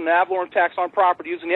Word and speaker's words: ad valorem: It's ADVALOREM.